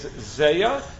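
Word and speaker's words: zeya (זיעה): Just to be clear, the way to pronounce the word in this context is zeya. Zeya means sweat or steam, depending on the context that you're discussing. Not to be confused with the word zia,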